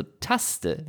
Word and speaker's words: Taste: Aber da ich ja auch einen Bildungsauftrag habe, wenn der werte User Teddy Taste eine Hommage an Dieter Krebs ist, dann wird das aber deutsch ausgesprochen. Also Taste, wie in Tastatur, gab ja neben Theo Tusch am Schlagzeug und Teddy Taste, am Klavier auch noch Bobby Backe, an der Trompete.